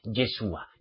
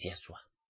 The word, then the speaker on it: Yahshua: Yeshua, Yeshua, Mesía, es Yahshua. No Ye, es Ya.